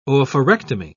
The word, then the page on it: oophorectomy: British English